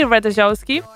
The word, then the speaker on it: Ratajkowski: Emily Ratajkowski,